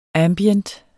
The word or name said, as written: ambient